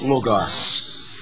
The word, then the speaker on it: logos: Word is the translation of a Greek word pronounced logos, which was used by some Greek philosophers of the day, interestingly enough.